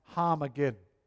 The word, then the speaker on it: Harmageddon: In the Hebrew, it's pronounced Harmageddon with H. Har meaning mountain, Megiddo meaning slaughter.